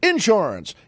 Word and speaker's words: insurance: And one of his characters was Senator Claghorn, who had that great thing of putting the accent on the first syllables, like people. A lot of people from the South say that it's insurance. You got to get yourself some insurance.